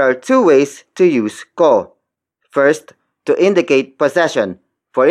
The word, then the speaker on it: ko: Kotse ko yan. The first word is kotse. Kotse. Kotse. Kotse is spelled as K-O-T-S-E. It means car. It is borrowed from Spanish. The next word is ko.